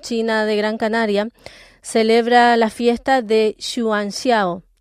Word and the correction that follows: Yuan Xiao: Perdón se lo he dicho mal, Yuan Xiao, mejor dicho,